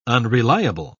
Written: unreliable

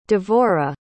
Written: devora

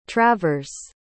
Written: travers

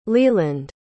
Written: leeland

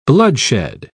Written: bloodshed

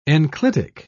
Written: enclitic

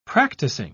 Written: practicing

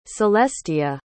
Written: celestia

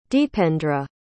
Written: deependra

